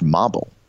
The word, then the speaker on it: Mhabil: And it's spelled, and he said it was okay for me to even spell his name, M-H-A-B-I-L, pronounced Mobble.